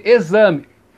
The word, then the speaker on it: exame: E exame. Exame né? é escrito com X mas o fonema é o Z.